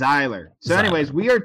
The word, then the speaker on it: Zahler: It's Z-A-H-L-E-R. Zahler. – Yeah. – Pronunciation. So today, everybody, we are talking about... Hold on. We are talking about this man. S. Craig Zahler. So it's like xylophone, but Zahler.